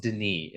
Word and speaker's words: Denis: And if you have a Patreon account with us, which we do need to answer a question from Will Dennis. – It's pronounced Denis. It's French.